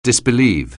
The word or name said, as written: disbelieve